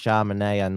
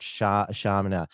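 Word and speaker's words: Shamima: Oh my God, it's pronounced Charmanea, not Charmina.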